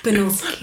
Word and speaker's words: Banulski: Banulski.